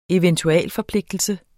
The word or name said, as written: eventualforpligtelse